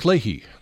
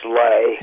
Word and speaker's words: Leahy: His name is actually pronounced Lay. – Lay, okay. – Spelled L-E-A-H. Mick Lay is how he pronounced it.